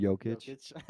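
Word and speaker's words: Jokić: You gotta go Jokovic. Like I feel like. – Oh my! Did I butcher the name of it? Jokic. Jokic.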